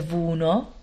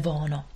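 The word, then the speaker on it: Voono: Píše sa to V-O-O-N-O. A pre tie z vás, ktoré ste zvyknuté na angličtinu, tak občas vyslovujete Vono, ale my u nás vo firme vyslovujeme Vono.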